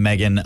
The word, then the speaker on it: Meghan: The Oprah interview with Harry and Meghan about the concern for their child's appearance. – That's the correct way to say it.